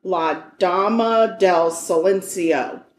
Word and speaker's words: La Dama del Silencio: So her name, her professional name was Lady of Silence, which is pronounced La Dama del Silencio.